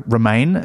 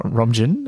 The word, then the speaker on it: Romijn: I'd love to see Rebecca Romijn, as you have taught me is pronounced, not Romjin, because I've been misreading it all these years.